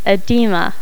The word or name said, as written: edema